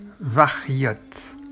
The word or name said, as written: vaghiot